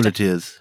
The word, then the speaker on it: muleteers: Muleteers.